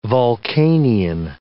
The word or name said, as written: vulcanian